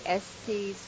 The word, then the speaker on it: Estes: There's been some debate about the pronunciation of one of the main streets in Chapel Hill. We hit the streets to find out how you say it. Estes Drive or Estes Drive? – I live in Chapel Hill. I say Estes Drive.